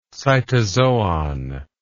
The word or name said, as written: cytozoic